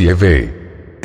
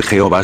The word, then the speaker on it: Yahvé: El Dios Padre tiene un nombre, el cual es el nombre sobre todo nombre, y parece ser que la pronunciación más exacta de este nombre del Padre en idioma hebreo arcaico es Yahvé. – El nombre Jehová